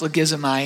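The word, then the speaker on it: logizomai (λογίζομαι): That word credited is a Greek word. It's pronounced logizomai, and it's an accounting term.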